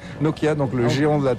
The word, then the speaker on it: Nokia: Mais il ne faut pas accentuer la prononciation de chaque lettre. – Non. – Nokia. – Non. On pourrait, mais c'est bon aussi Nokia.